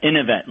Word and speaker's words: InnoVet: Yeah, InnoVet, like innovation.